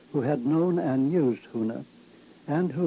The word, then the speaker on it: huna: In Hawaiian, the word for secret is huna, which is spelled H-U-N-A, huna.